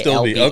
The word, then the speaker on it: astilbe: Is it a stilby or a stilby? – It's one word. A-S-T-I-L-B-E. – A stilby.